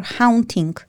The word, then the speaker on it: haunting: I don't know, was it hunting me or haunting? How do you pronounce it? Because one is when you hunt animals. Hunt? – Haunting. – Haunting, like a ghost? – Yeah. – Haunting. – Yeah. – A Dane is teaching me the English pronunciation. That is fantastic. Haunting, you are right.